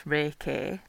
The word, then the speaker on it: Reiki: So Reiki is a word pronounced Reiki, R-A-Y-K-E-Y, and it means universal life force.